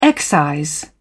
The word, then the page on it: excise: British English